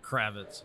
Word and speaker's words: Kravitz: Kravitz.